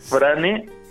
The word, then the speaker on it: Frane: Frane. – Frane. ¿Y Cela con Z? – Cela con S-E-L-A-K.